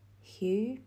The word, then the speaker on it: Hugh: So the next name is Hugh, H-U-G-H. And it is a simple one-syllable name, but with a huge meaning.